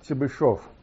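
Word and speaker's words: Chebyshev: And a few words about this function psi, which was introduced by a Russian mathematician, and his name should be pronounced Chebyshov.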